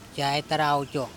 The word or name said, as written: cay tara oco